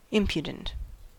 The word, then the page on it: impudent: American English